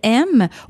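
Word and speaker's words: Em: E-M. Mais ça se lit vraiment comme « aime », comme le verbe « aimer » à l'impératif.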